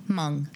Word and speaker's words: Hmong: When I was welding, there was a, I think he's, how do you say, Hmong? Hmong? – Hmong. – It's just a silent. – It's Hmong.